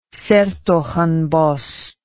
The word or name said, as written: shertogenbosch